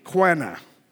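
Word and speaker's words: Qanna: It is spelled Q-A-N-N-A. I would pronounce it this way, Quana.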